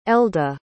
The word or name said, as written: elda